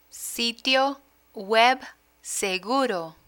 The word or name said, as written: sitio web seguro